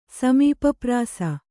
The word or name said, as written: ಸಮೀಪಪ್ರಾಸ